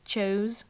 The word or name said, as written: chose